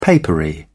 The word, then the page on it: papery: British English